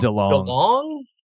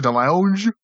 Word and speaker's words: DeLonge: How do you say his last name? I always say it wrong. – DeLong. – DeLong, that sounds right. Better than what I would have said. I was going to say DeLonge.